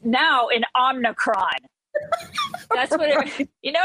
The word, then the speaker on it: Omicron: There's no N except for the very end. It's Omicron.